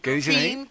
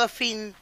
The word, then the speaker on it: Dauphine: Yo les decía hoy temprano es el Dauphin, que es parecido al Gordini. – Claro. – Ah. ¿Cuál? – ¿Me entendés? El Renault Dauphin. Ajá. Dauphine se escribe.